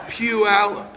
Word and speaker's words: Puyallup: And you're not having, how do you pronounce that town that looks like Polly up? I knew I had it wrong. Say it again. Puyallup. One more time.